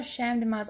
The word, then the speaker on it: Champ de Mars: Oh, Champ de Mars. I've been saying Camp de Mars.